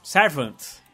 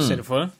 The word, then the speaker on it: Servant: Servant né? Que sempre hum. falo servant. – Me parece muito uma palavra francesa e aí acabo falando errado. É, ser, é servant.